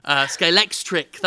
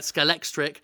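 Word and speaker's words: Scalextric: It's basically like a Skelextric. Skelextric, that's how you say it, Skelextric.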